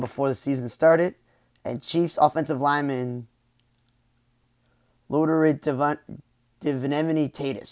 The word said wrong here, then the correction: Duvernay-Tardif: Sorry, like if I pronounce Laurent Duvernay-Tardif, I know he's Canadian, he's a right guard, He's a doctor.